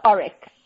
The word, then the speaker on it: Oryx: Yeah, we pronounce it Oryx as opposed to Oryx. So Oryx Desert Salt. – Oryx, but it's spelled O-R-Y-X. – That's correct, yes. – Okay, well, I have to correct that. – So the Y is pretending to be an I.